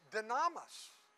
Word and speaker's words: dynamis: And the guy said, it's dynamis.